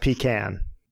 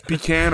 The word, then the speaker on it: pecan: It's pronounced pecan. – Yeah, it's pecan or pecan.